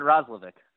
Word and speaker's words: Roslovic: I think everyone here has pronounced it Roslovic to this point, so kind of a hard C.